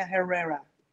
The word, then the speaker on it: Herrera: Pero es que estás pronunciando mal, Julio. Se pronuncia Carolina Herrera. Herrera.